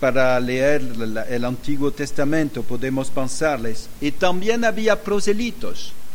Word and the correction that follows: prosélitos: Prosélitos, sí. Sí, pronunciación de español y no del francés.